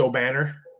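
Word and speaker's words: Obanor: It's Obnafor. Is that correct? – From O'Banner. Kevin O'Banner from Oral Roberts. That's how you pronounce it? It's O'Banner? – Yep. – Okay, it doesn't look spelled that way.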